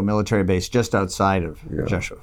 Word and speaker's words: Rzeszów: Rzeszów? – Yeah, very good.